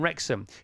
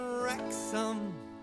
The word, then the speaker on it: Wrexham: with Wrexham, and that's got a silent W.